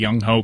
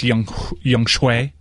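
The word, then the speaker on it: Younghoe: I believe Gumbel was pronouncing it Young Shui, but if you see it, it looks like Young Ho Koo, but it's Young Shui.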